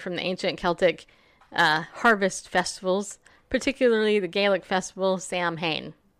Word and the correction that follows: Samhain: Sowing. – Did I say it wrong? – It's easy to, because whatever. – Gaelic is spelled nothing at all like it's pronounced.